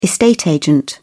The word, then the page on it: estate agent: British English